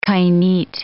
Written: kainit

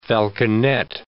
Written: falconet

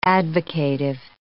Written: advocative

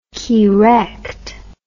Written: keerect

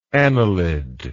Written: anilide

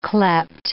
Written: cleped

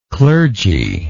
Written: clergylike

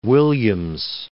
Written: Williams

